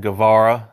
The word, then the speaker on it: Guevara: Guevara.